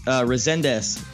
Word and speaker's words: Rezandez: It's okay. It's Portuguese. Rezandez.